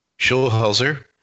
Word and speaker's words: Schulhauser: Schulhauser. – Schulhauser, I said school.